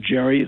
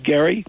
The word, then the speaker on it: Gerry: It takes its name from Elbridge Gerry, although it's spelled Gerry, it's pronounced Gerry,